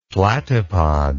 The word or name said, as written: platypod